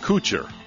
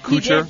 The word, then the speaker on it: Kutcher: Ashton Kutcher. Did I say it right? Kutcher? Kutcher.